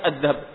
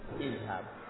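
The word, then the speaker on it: idhab (اذهب): Beda dengan idhab. – Huruf pertamanya sebelum dikasih alif sukun. – Susah untuk dibacanya adhab, adhab. Susah, akhirnya kasih idhab.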